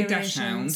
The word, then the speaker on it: Dachshund: I know there's a lot of some people say Dachshund, so I've researched it and it's pronounced Dachshund.